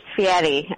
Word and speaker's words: Fieri: Yeah, it's pronounced Fieri, okay.